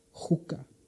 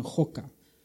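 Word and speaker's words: juka (חֻקָּה): Aquí pone con ch, pero en griego realmente se pronuncia con j, juka. Otros diccionarios ponen joka, pero es lo mismo.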